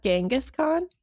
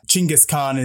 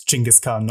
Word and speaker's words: Chinggis Khan: Yeah, Chinggis Khan is a more accurate pronunciation. – Oh my god, this is fun. Yeah. Just the difference. – I think there's an even more accurate pronunciation than that, but I can't remember what it is. I just remember that it's Chinggis Khan, not Genghis Khan.